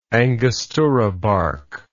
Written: angostura bark

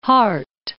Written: Harte

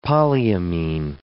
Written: polyamine

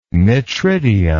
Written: metridium

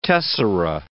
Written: tessera

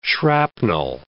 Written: shrapnel

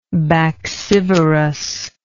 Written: baccivorous

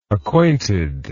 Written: acquainted

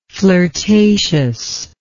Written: flirtatiousness

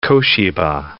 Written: Koshiba

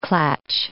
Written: klatsch

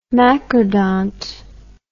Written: macrodont

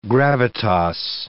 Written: gravitas

